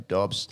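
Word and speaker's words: Doubs: Because it's spelt like doob, like smoking a doob. Oh. But, yeah, it's pronounced Romeo Dobbs.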